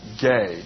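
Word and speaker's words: gay (γῆ): One word is gay, not G-A-Y, but like a G-A, long A, gay.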